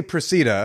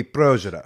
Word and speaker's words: Procida: We say Procida. – Okay, but in Italian it would be Progida.